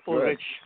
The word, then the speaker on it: Ulrich: Yes, you pronounced my name right. It's Ulrich Floresca.